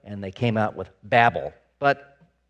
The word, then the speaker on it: Bavel: But what's interesting is in the Hebrew, it's Bavel. So if you want to pronounce this, if you want to say this accurately, the way they said it all those years ago, it's Bavel, right?